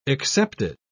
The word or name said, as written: excepted